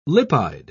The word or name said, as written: lipide